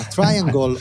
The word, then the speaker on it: Triangle: Triangle, no scusami, abbiamo controllato la pronuncia prima, Triangle